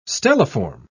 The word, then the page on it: stelliform: British English